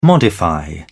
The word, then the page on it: modify: British English